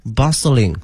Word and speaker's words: bustling: 同学们注意一下，这个 t 呢是不发音的 bustling 哈。